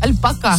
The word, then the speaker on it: альпака: Альпака на последнее А ударение.